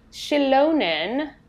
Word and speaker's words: Xilonen: The deity Shilonen, I'm assuming that's how it's pronounced, X-I-L-O-N-E-N. – Mm-hmm. Yes, that's correct.